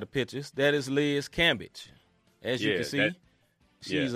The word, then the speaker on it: Cambage: All right, so the one word of the day, y'all can't see it, but I'm going to put it up here, is Liz Cambage. Is that how you say it, Rob? – Yeah, it's Liz Cambage.